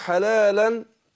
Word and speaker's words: حلالا: حلالا، هنا تنوين بعد الطاء، الطاء طبعا من حروف الإخفاء. ما أنا بقول حلالا هي أصلها تنوين بس بتنطق نون دون الكتابة، مش بتكتب لكن بتنطق في اللفظ.